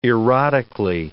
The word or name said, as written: erotically